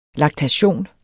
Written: LAKTATION

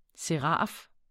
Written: seraf

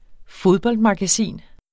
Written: fodboldmagasin

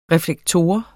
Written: reflektor

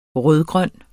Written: rød-grøn

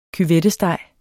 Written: cuvettesteg

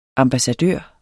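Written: AMBASSADØRERNE

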